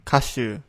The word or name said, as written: kashu